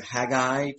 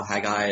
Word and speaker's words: Haggai: I remember teaching on this about a decade ago and having to figure out if it's pronounced Haggai or Haggai to preach. And I think the two-syllable version is preferred.